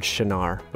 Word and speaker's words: Shinar: shinar, which is a place.